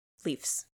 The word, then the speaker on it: Leafs: The Leaves. – Leafs. – Leaves. Leafs. – It's pronounced Leafs.